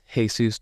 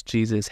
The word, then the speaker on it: Jesus: I like to say that you can pronounce that one of three ways. Jesus, Jesus, Jesus. Fine with all of the above.